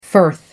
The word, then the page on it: firth: American English